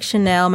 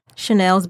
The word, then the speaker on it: Shanel: And you can just do hashtag Chanel. My name is spelled S-H-A-N-E-L, Chanel's Book Club.